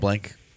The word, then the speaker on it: Blanc: Mel Blanc. – Mel Blanc. Even though it's the C.